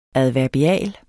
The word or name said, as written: adverbial